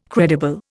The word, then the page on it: credible: Indian